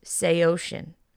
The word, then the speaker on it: Saosin: And then Anthony Green confirmed on Twitter when people how were bringing this video to his attention that the band is called, it's pronounced Seosin. And they're spelled S-A-O-S-I-N.